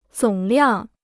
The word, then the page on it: 总量: zǒng liàng